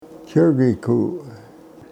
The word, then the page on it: chrgiku: Yurok